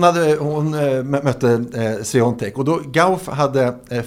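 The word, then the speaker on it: Świątek: Hon får möta Sabalenka, som är världsetta, eller Iga Sviontek, som det uttalas, fast det inte stavas riktigt så, som spelar semifinal typ när som helst.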